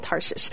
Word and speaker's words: Tarshish: It always sounds like I'm saying that wrong, but the city is called Tarshish.